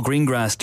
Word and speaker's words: Greengrass: Also, we get very serious with Paul Greengrass, director of 22 July. And yes, that's how he pronounces it.